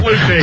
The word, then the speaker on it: Flutie: No, I like the way you pronounced it the first time. Flutie.